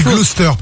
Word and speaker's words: Gloucester: Alors on dit Gloucester pour info, ça s'écrit Gloucester, mais c'est, non, c'est pour ton info.